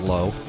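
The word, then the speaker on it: Zlo: I used to pronounce it Z-L-O. I now know it's pronounced Zlow.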